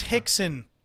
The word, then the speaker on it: Rickson: In discussing Kunin, Gus Johnson said, once referred to as the female Rickson Gracie. It's Hickson, Gus. Like, when you Portuguese, Brazilian names, you say they start with an R, they're pronounced with an H.